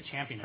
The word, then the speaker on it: champing: Champing. – CHAMP. I was pronouncing it with a long A sound.